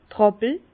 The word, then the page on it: d' Ràppel: Bas-Rhin; Herrlisheim